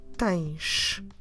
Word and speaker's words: tens: When we write it with the letter S, but then when we hear it, we hear a sh. So that's what you have on tens.